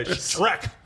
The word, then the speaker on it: Shrek: You're pronouncing it like S-H-T-R-E-K. Shrek.